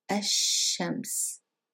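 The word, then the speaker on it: Ashams (الشمس): So we said that because sheen is a sun letter, we don't pronounce the lam and we double the sheen. So Ashams, Very good.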